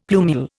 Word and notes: plumule: Indian

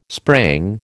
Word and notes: sprang: American English

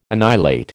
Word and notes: annihilate: American English

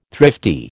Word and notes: thrifty: American English

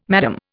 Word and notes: madam: Indian

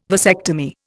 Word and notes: vasectomy: Indian

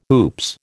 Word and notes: oops: American English

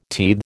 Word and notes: teethe: American English

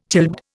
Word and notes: childbed: Indian

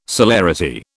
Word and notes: celerity: British English

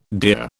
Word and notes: deer: British English

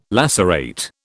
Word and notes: lacerate: British English

